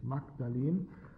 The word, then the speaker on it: Magdalen: Das ist die Bibliothek des Magdalen College in Oxford, Papyrus Magdalen Greek 17, die Nummer 17, Magdalen, das ist ja auch, das wird wirklich so ausgesprochen, Magdalen geschrieben, nicht Magdalen. Aber, und sie würden, wenn das in einer englischen Bibel vorkommt, würden sie Magdalen dazu sagen, wenn von Maria Magdalena die Rede ist, Magdalen, aber da ist der Name eines College in Oxford ist, hat es die alte mittelalterliche Aussprache und die ist Magdalen.